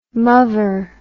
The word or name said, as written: muvver